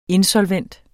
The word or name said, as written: insolvent